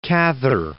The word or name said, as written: Cather